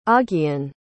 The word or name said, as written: augean